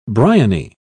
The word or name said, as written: briony